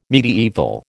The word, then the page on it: mediaeval: American English